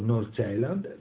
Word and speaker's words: North Zealand: In questo caso abbiamo la prova provata con North Zealand, così si pronuncia, che dal torneo di Viareggio addirittura va alla Coppa dei Campioni.